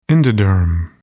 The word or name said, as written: endoderm